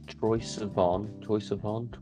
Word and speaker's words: Troye Sivan: Troye Sivan, is that how you say his name? – Yeah. – Troye Sivan, I have recently got back into.